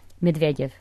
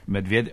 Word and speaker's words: Medvedev: Medvedev. – Medvedev.